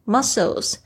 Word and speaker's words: muscles: muscles, muscles, m u s c l e s, muscles。这个 s c 哦，就有发 s 的声音哦，大家不要变成 muscles，不是 muscles，muscle 是肌肉，不是那个肌肉哦，不是 chicken 哦，是你身上的肌肉。